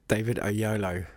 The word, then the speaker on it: David Oyelowo: I said it right earlier. David Oyelowo. That's right, isn't it? – David Oyelowo. – A YOLO. All right, okay, I've been saying it wrong.